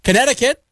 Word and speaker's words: Connecticut: H-A-W-A-I-I. Hawaii there. Pennsylvania, that's a tough one for some people. P-E-N-N-S-Y-L-V-A-N-I-A. Pennsylvania. Connecticut, just connect, I cut, right? C-O-N-N-E-C-T-I-C-U-T.